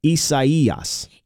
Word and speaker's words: Isaias: You know, technically, you're supposed to pronounce that Isaias. – Isaias. Okay. Yeah. – But I think most people are saying Isaias.